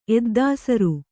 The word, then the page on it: ಎದ್ದಾಸರು: kannada